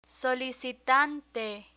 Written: solicitante

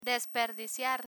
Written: desperdiciar

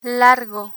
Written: largo

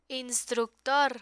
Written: instructor

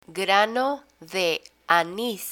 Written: grano de anis

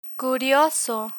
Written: curioso

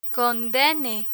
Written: condene

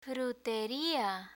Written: fruteria

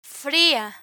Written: fria

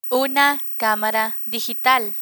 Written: una camara digital

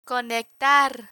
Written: conectar

